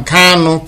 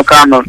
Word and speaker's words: Kanu: Okay, Nnamdi Kanu. – I resent to be called Kanu. I am Kanu, K-A-N-U. – Kanu. – The full pronunciation is Kanu.